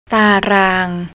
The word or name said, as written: tarang